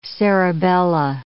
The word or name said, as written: cerebella